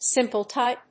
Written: simple-type

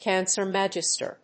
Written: cancer magister

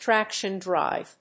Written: traction drive

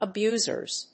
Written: abusers

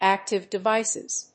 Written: active devices

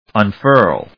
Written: unfurl

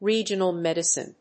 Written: regional medicine

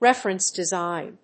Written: reference design

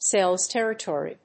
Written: sales territory

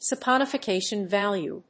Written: saponification value

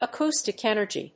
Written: acoustic energy

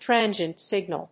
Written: transient signal

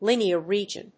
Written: linear region

